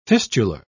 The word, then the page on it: fistular: British English